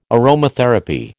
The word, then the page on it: aromatherapy: American English